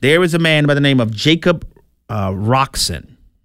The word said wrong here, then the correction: Coxon: Is that say his name right? Let me make sure I got it. Coxon. I'm sorry.